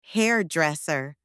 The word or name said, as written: hairdresser